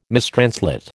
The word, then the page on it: mistranslate: American English